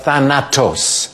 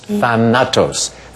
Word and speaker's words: Thanatos: Zachary Mann, what is Thanatos? Thanatos. Three syllables.